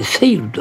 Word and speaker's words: 星流: でこの孫であります星流れると書いてセイルと呼びます。